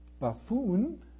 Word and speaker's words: buffoon: And he had to think about, I said, buffin. How do you pronounce buffin? He said, buffin, buffin, buffin. Oh, you mean buffoon? Correct? Buffoon. Yes, buffoon. Okay, I got that.